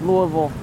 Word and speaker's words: Louisville: I still struggle because Colorado is Louisville. – Yeah. – And then here it's Louisville. I'm like, okay, gotta differentiate the two.